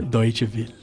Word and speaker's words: Deutsche Welle: Ó, a pronúncia como é que é? Deutsche Welle. Ó, gostei.